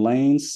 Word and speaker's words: Lanez: lanes. So L-A-N-E-Z is pronounced Lanes in his name.